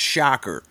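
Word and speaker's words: Schacher: And it's the and Mel's last name is pronounced Shocker. – Shocker. – Like, you know, yeah, if you stuck your fingers in a light socket, you'd get a shocker.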